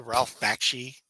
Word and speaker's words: Bakshi: Is that the Ralph Bakshi? – Yes.